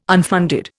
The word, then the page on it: unfunded: Indian